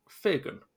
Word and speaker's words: Firgun: But actually the one I keep coming back to is, and I think you pronounce it, Firgun. F-I-R-G-U-N. It's a Hebrew word, apparently.